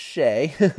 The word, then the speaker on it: Hsieh: Now, Tony Hsieh is his last name is spelled Hsieh, but it's pronounced Shea.